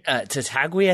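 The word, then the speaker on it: Tatague: How do you say that again? Nick Tataguy? – Tataguy, I think it is. It's T-A-T-A-G-U-E. But I think we've been slowly coached over the years to pronounce it properly.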